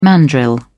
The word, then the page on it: mandrill: British English